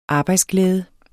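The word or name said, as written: arbejdsglæde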